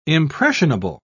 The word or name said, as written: impressionable